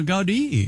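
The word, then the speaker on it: Gaudí: But Gaudi is really, he had quite the vision for that. – Oh, Gaudi. – Yeah, that's how they pronounce it.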